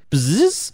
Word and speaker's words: Pzizz: Bzzz. This is spelled P-Z-I-Z-Z. How would you pronounce that? – I think you nailed bzzz.